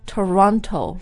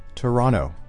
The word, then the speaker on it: Toronto: Yeah, so there is an N-T-O on the N, but in English we often don't say the T after an N. – 所以你再说一遍 one more time, how do you say it? – Toronto. – Toronto. – Yeah, yeah, Toronto. – Yeah. – It almost becomes like a D sound. – Actually, the T just disappears, like the way we say internet.